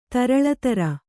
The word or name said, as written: ತರಳತರ